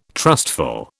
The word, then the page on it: trustful: British English